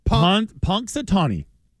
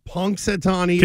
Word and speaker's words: Punxsutawney: Punxsutawney. – Punxsutawney. – Can you spell it? – Hold on. P-U-N-X-S? Close. – T-A-W-N-U-Y.